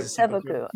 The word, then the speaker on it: seppuku: I think it's seppuku. – Seppuku. – Yeah, okay. With a P.